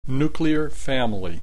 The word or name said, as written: nuclear family